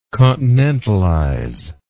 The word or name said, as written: continentalization